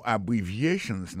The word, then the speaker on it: abbreviations: Abbreviations, accent tonique la, tombé sous troisième syllabe la. Dans mot ça, il y a quatre syllabes. C'est A, bon syllabe, bri, c'est deuxième syllabe là, vie, c'est troisième syllabe là, chan, c'est quatrième syllabe là.